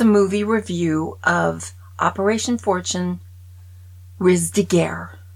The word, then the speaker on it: Ruse de guerre: That's how you say it. Watch this. – French. Riz de Guerre. French. Riz de Guerre. French. Riz de Guerre. French. – See? – Riz de Guerre. – It's not even hard. But Americans can't speak French. They won't use their accent.